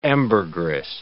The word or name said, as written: ambergris